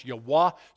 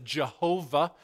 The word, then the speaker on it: Yahweh: Some people pronounce it Yahweh. It's really pronounced Yahweh. Some people totally botch it up based on a Middle Age misinterpretation and pronounce it Jehovah.